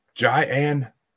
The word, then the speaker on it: Jaian: And our guest this week, and let's see how bad I butcher this, is Jaian.